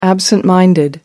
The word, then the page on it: absent minded: American English